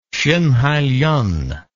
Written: sinhailien

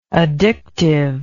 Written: addictiveness

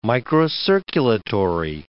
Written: microcirculatory

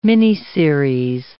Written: miniseries

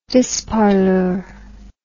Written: disparlure

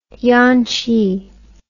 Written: yanqi

